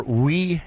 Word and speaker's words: Wii: But we do have one write-in, and it was sent via fax, so we're going to do that right away, so while we still have time. They are looking for Wii games. That's W-I-I, pronounced W-E.